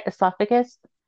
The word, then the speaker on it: esophagus: It says like her tongue and ex- how do you say that? Escapula? I can't say that word. It's like the inside, like esophagus. Is that right? Esophagus. Anyways, they're saying like yeah, that entire. – Am I saying that right? Yeah, esophagus. That's how you say it.